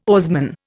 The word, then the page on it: oarsman: Indian